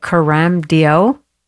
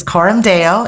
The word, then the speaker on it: Coram Deo: Coram Deo. Is that how I say that? – No, it's Coram Deo, and it's Coram Deo. See, I was way off.